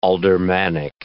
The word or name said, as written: aldermanic